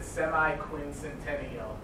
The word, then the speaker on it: semi-quincentennial: The semi-quincentennial.